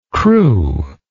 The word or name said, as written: crewless